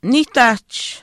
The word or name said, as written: niihtaahch